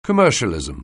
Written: commercialism

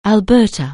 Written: Alberta

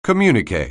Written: communiqué

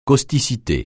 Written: causticité

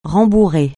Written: rembourrer

